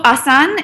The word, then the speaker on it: Asan: So Asan, it's, you know, you might be familiar with the word.